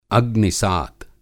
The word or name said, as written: अग्निसात